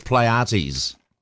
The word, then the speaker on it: Pleiades: It's Pleiades. I was a bit unsure about pronunciation of that word. So, I think it's Pleiades.